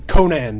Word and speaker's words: Conan: Then, is this one pronounced Conan the Barbarian or Conan the Barbarian? – Conan the Barbarian. – That's what I thought.